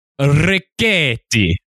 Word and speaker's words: Richetti: So our Italian archaeologist on board, his name is spelled R-I-C-H-E-T-T-I. And we have been saying Ricchetti or Ricchetti, right? – Okay. – I was told, and I looked this up and verified it, the proper pronunciation, again, thank you, dear listener, is actually, and I'm going to put up my Italian hands here, Ricchetti. – Okay. – So from now on, it's Ricchetti.